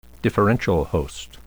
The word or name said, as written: differential host